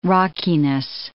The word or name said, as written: rockiness